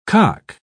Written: cock

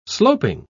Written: sloping

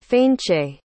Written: Fainche

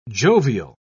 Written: jovial

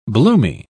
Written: bloomy

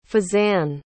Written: fezzan

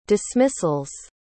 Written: dismissal's

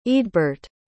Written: eadburt

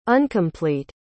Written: uncomplete

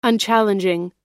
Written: unchallenging

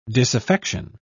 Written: disaffection

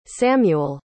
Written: samuel

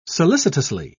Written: solicitously

